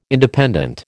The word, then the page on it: Independent: American English